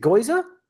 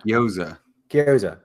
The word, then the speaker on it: gyoza: Is it Goiza? – Gyoza? – Yeah, I was just saying Gyoza. – Yeah. – Gyoza.